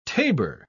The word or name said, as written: tabor